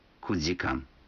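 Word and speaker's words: кудикан (九時間): Некоторые цифры в этих случаях произносятся не так, как обычно. 4 часа – это йодикан, 7 часов – ситидикан, а 9 часов – кудикан.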